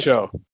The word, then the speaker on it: Acho: Acho.